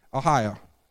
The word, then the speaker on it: Ahio: Ahio. You just got to say it like you're from... Yeah, from the south.